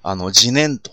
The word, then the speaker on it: 自然: 浄土系ではね、仏教のこの浄土系では、自念と、やはり自念という言い方をするんですよね。自然と書いて自念ね。